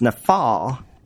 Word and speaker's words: NAFAL: NAFL is actually an abbreviation. It's actually pronounced NAFAL.